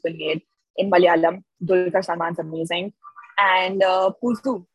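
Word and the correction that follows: Puzhu: am I saying it right? Puzu? Puzu's Pulu. – Great. – Pulu, because... Pulu, yeah.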